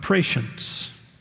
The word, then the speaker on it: prescience: It is called the doctrine of prescience. Prescience, if you want to know how to spell it, pre-science. Pre-science.